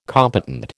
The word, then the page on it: competent: American English